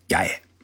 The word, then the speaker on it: 八重: 在与政府军的决战过程当中，涌现了一名勇敢的女性，她的名字叫八重，日文呢念作雅野。